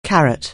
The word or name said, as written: carat